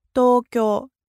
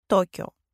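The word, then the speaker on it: токё (東京): Знаком двоеточия обозначается долгота предыдущей гласной «токё», «токё».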